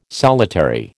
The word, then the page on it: solitary: American English